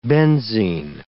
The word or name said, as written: benzene